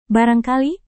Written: barangkali

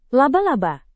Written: laba-laba

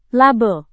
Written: label

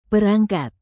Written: perangkat

